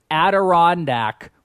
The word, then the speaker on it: Adirondack: By the Adrianic Mountains or whatever it was. We could not have gotten more crap about mispronouncing these mountains. – Guess what? We got more interaction about screwing up Adirondack.